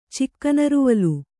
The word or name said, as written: ಚಿಕ್ಕನರುವಲು